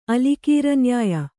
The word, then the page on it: ಅಲಿಕೀರನ್ಯಾಯ: alikīraNyāya